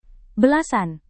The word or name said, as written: belasan